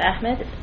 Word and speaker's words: Ahmed: B-O-R-G-E-R-S is my last name. – Shaista Ahmed, S-H-A-I-S-T-A-A-H-M-E-D with the FCIC. – Henry Albulescu, A-L-B-U-L-E-S-C-U, with Standard & Poor's.